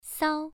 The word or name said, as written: sao1